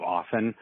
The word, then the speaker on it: often: With the T in the middle, people are saying often, rather than the proper pronunciation of often, like the words soften or listen or hasten. The T, the medial T, is silent.